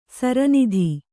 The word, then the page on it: ಸರೋನಿಧಿ: sarōnidhi